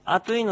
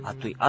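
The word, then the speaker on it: アトゥイ: で、普通この、あといのトゥーっていうのはですね、トに丸で、をつけて書く、アイヌ語の特殊な発音です。トにちっちゃいウも、ウでもいいんですけどね。あとい。あといじゃなく、あといでもないし、あついでもない。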